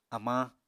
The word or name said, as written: amañ